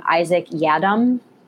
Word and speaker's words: Isaac Yiadom: Isaac Yiadom. – Yeah, you got it. That was it.